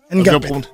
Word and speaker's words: Ngapeth: Et pour ça, nous sommes avec Erwin Ngapet. Est-ce que j'ai bien prononcé Ouais. Ngapet. – Ngapet. Ngapet. Voilà.